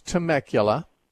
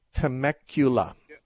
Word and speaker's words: Temecula: And the town is Temecula. That's spelled T-E. That's T as in Tom, E-M-E. It's a T-E-M-E-C-U-L-A, Temecula, California.